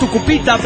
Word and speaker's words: Tucupita: Antaro de Tupusita, Venezuela. – Hola, Antaro. – En realidad es Tucupita, pero no importa.